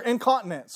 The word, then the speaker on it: incontinent: So the absence of temperance in one life would mean that they are incontent or incontinent.